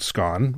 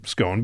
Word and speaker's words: scone: Sarah Merker has one pronunciation of the word scone, or scone, but she has made many pronouncements about scones, or scones.